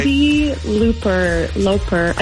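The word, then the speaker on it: Cyndi Lauper: Cindy Lauper.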